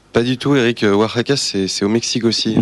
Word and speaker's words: Oaxaca: À l'autre bout de la planète, à Oaxaca. Bonjour. – On prononce Oaxaca, Eric. – Oaxaca, très bien.